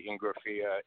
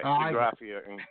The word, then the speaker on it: Ingrafia: If you were in Italy, you would say Ingrafia. If you were in Los Angeles, you say Ingrafia. So either way, Ingrafia, as long as the check doesn't bounce, I'm good with either way.